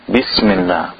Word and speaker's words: bismillah: So for example, bismillah, bismillah has the strength. Not bismillah, bismillah, it makes it a soft letter. Bismillah, it's a letter of shidda.